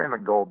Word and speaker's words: McDowell: I say McDowell because that's the way they pronounce it there. It's spelled like McDowell, like the county.